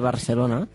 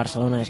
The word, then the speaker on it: Barcelona: I llavors, eh, la, la resposta s'ha de tenir en compte la pronúncia de Barcelona amb la A, recordem, recordem que la pronúncia de Barcelona és la A, no?